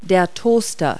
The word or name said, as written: toaster